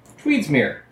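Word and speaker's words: Tweedsmuir: Then it's like M-U-I-R. – Tweedsmere.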